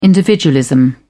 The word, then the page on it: individualism: British English